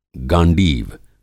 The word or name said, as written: गांडीव